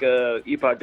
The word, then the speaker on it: Epoch: So these days, I work at a shop in Austin, Texas called Epoch Coffee, spelled not how it sounds. The word epic, as in a point in time, E-P-O-C-H. We pronounce it Epoch because it's not a real word, I guess.